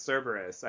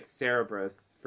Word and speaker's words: Cerberus: I hate when that happens, but I think that happened with Cerberus. I think I was reading it Cerebrus for years, mm-hmm.